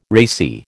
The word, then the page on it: racy: American English